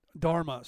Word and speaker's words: Dharma: Santana drama is the Hindu way of life. We teach the creator. Did I say that right? Santana Dharma. Sorry, I didn't see the R there.